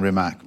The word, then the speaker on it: Rimac: Or should I say Rimac's? Are you pronouncing it properly? No, we're saying Rimac.